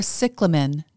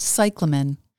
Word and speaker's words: cyclamen: Those lovely winter flowers that look like the shooting star wildflower but with marbled heart-shaped leaves are cyclamen, not cyclamen. The name derives from the Greek kyklemenos. That first syllable is a kick, not a sigh.